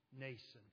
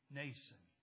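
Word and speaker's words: Mnason: And it's found in his name, Nason. I know there's an M in front of it. I got out my Alexander Scorby tapes and listened so I'd know how to pronounce it right. Nason.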